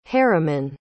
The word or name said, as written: heremon